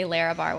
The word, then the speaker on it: Larabar: No, I'm just saying that's how you say Lara Bar, which most people mispronounce.